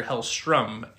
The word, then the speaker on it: Hellstrom: I kept referring to him as Hellstorm. His name is Major Hellstrom, Hellstrom. So, I did mention it during the review that I was saying it wrong, but as always, I want to bring it back up in housekeeping. – Yeah, of course. – It's Major Hellstrom.